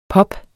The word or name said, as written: pop